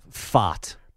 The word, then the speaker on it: Pfaadt: Pfaadt. – Brandon Pfaadt.